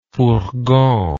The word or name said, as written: fourgon